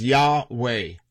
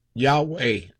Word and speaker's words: Yahweh: His name is Yahweh. Of course, some people will pronounce it different, but Yahweh.